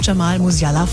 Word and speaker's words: Jamal Musiala: Joshua Kimmich vom FC Bayern und auch Jamal Musiala vom FC Bayern, richtig? – Ist sogar richtig ausgesprochen.